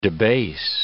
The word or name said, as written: debase